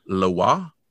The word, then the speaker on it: Loa: And anyone who has watched Kolchak the Night Stalker, the zombie from 1974, the second episode, might remember it because he actually, of course, mispronounces it as Lois, and he calls her like Mama Lois instead of Mama Loa.